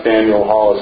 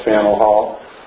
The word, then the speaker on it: Faneuil Hall: It's not Faneuil Hall, it's Faneuil Hall.